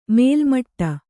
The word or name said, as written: ಮೇಲ್ಮಟ್ಟ